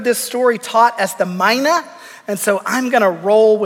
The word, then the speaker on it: mina: Now, that word mina, there is some discrepancy in its pronunciation. Some pronunciation guides say that it's actually mana.